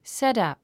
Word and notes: setup: sétʌ̀p; セットアプ

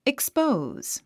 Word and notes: expose: ikspóuz; イクスポーズ

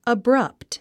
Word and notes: abrupt: əbrʌ́pt; アブラァプト

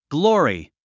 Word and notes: glory: glɔ’ːri; グローリィ

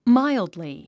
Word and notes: mildly: mǽildli; マイルドリィ

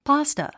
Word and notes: pasta: pάːstə; パスタ

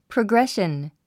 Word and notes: progression: prəɡréʃən; プログレション

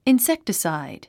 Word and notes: insecticide: inséktəsàid; インセクテサイド

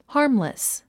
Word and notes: harmless: hάː r mlis; ハームレス

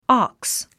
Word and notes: ox: άks; オォックス

